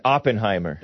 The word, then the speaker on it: Oppenheimer: Is that how it's pronounced? – I don't know. – I always read it as Oppenheimer. – That's what it is. – What a cool name, huh? Oppenheimer.